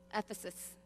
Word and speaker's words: Ephesus: Ephesus.